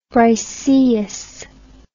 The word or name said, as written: Briseis